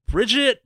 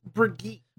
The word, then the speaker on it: Brigitte: All right, so tell me about, how do you even pronounce her name? Bridget? – So it's Brigitte. – Brigitte. – Yes.